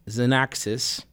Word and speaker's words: Xanaxis: And so I had gotten your newest single, which is pronounced Xanaxis, and that's spelled X-A-N-A-X-I-S.